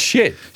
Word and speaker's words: Schiit: It's spelled S-C-H-I-I-T. It's shit. It's pronounced shit audio.